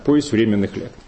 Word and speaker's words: Повесть временных лет: Может, вы даже обратили внимание, как я сейчас поставил ударение, потому что и вас, и меня учили несколько по-другому. Другое было ударение, но я, видите, не случайно ставлю именно ударение так, «Повесть временных лет».